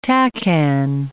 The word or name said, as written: TACAN